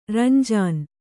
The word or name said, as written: ರಂಜಾನ್